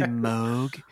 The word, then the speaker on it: Moog: It's actually Moog. – Because it's spelled M-O-O-G, and they've got something called a Mooger Fooger, which is not, again, are we really supposed to, is that a Mooger Fooger then? – Or a Mooger Fooger? – No, it's not. Anyway, it is pronounced Moog, but I say Moog most of the time because I don't want to sound like an a-hole.